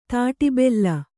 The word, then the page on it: ತಾಟಿಬೆಲ್ಲ: tāṭi bella